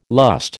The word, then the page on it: lust: American English